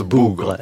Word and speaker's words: Bogre: Ça s'écrit Bogre, mais ça se prononce Bougre.